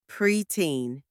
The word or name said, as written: preteen